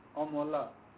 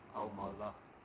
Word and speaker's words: Amala: A-M-A-L-A. – Pronunciation? – Omola. – Omola.